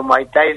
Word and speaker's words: Muay Thai: a ver si lo digo bien, ¿no? Muay Thai. – Sí, el campeón argentino de Muay Thai, lo has dicho de manera excelente.